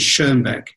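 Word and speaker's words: Schönbeck: Because our German name, the correct pronunciation is Schonbeck, but in English, obviously, people don't recognize the two dots over the O.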